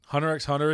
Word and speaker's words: Hunter x Hunter: Hunter x Hunter is getting, Hunter, sorry, you're not supposed to pronounce the X, people get mad, is getting a new volume.